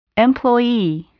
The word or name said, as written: employee